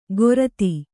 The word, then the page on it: ಗೊರತಿ: Kannada